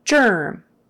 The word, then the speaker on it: germ: Spelled with a G, but you say it like a J. Germ.